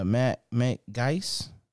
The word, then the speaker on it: Matt Gaetz: So we had a Republican, Matt Gaetz.